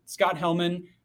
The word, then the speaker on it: Scott Hellman: As you mentioned, you got my name right, Scott Hellman.